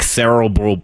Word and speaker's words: Cerebral: And when I see you hear me stumble on Cerebral all the time, but it's because when I was in back when I was in school, there we had a class of commonly mispronounced names. And Cerebral was one of them because it's cerebral, like cerebral palsy. And for me to say Cerebral is difficult because that's not how you pronounce the word.